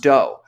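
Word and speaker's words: Do: He'd go by Doe, spelled D-O, but pronounced Doe.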